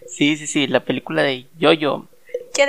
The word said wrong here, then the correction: Jojo: le digo Jojo, porque así se escribe.